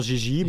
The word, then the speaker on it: JJ: mais mon prénom c'est deux fois la lettre J. – Ouais. – Au Québec, c'est ça se prononce Gigi, mais après ça, JJ, c'est aussi...